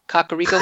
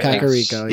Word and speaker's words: Kakariko: Kakariko. – I think that's right. – Kakariko.